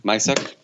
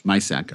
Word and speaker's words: Misak: There's this short, I don't know if I'm pronouncing his name right, but Charlie... – Misak. – Misak, okay.